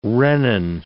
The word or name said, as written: rennin